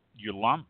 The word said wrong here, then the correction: Yalumba: Yalumba, I guess it's pronounced Yalumba.